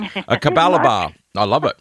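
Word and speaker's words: Capalaba: How do I pronounce where you're from in Queensland? – Kapalabar. – Yeah.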